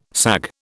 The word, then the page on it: sag: British English